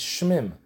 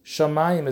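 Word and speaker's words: שמים: If you look at the word, maybe it's shmim. Shamayim, as if there's an aleph between the shin and the mem. So this is all part of Halacha L'Moshe Mesinai, how to read this word, that you pronounce it shamayim, mitzrayim.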